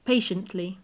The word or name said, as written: patiently